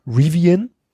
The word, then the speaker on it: Rivian: Es kommt von der mir bis dato völlig, es ist ein US-Startup, der heißt, jetzt können wir wieder diskutieren, wie er ausgesprochen wird, Rivian, R-I-V-I-A-N, Rivian, Rivian.